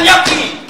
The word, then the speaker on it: Gnocchi: Folgt ein N auf ein G, dann muss man es wie K aussprechen. Das ist die Regel. Darauf muss ich leider bestehen. – Es heißt aber Gnocchi!